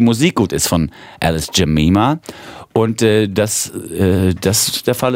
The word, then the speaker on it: Jemima: So will ich sie aussprechen. Jemima wird's geschrieben. Wir haben vorhin mal nach US-amerikanischen Aussprachen im Netz geforscht. Die sagen alle Jemima. Alice kommt aus England. Vielleicht sagt man da auch Jemima.